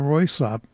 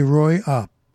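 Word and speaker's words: Røyksopp: There's a group that I play as often as I can, and they're called, I was pronouncing it Roysop, and I guess that's wrong, so I looked it up just to make sure, and by gosh, there's two possibilities they give me. I could say Roysop, or I could say Roy-op, with a silent K and S in it.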